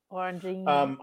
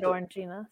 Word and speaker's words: Orangina: Orangina. – That's very good. Orangina. – I never had to pronounce that. – On Frasier, they said Orangina, so that's how I say it.